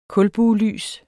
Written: kulbuelys

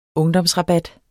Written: UNGDOMSRABAT

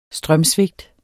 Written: strømsvigt

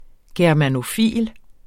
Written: germanofil

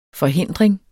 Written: forhindring

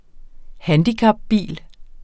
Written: handicapbil

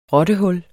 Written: rottehul

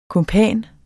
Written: kumpan

